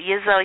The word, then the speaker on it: Yizo: was it Yizo? Is that how you pronounce it? – Yes.